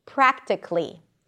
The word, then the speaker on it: practically: It ends with a k sound and then li. Practically. Practically, even though you still write the A, but it's not practically. Politically, not politically. It's probably not a mistake to pronounce the schwa, but usually people don't pronounce it. They just connect the k and the L.